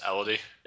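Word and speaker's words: Élodie: Elodie Young? – Oh, yes. Elodie. – Elodie? Is that how you pronounce it? – It's a French name.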